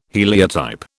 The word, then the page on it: heliotype: British English